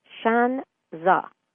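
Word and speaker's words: shan za (山楂): But the hawthorn berry, shan za is how it is pronounced in Chinese, S-H-A-N-Z-A.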